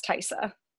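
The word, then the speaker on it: Taissa: That's how you say her name. Good. – I've never known how to say her name. Thank you. – I can't say names, so... – I'm pretty sure that's how she... I mean, I know it's Farmiga. I'm pretty sure it's Tyssa.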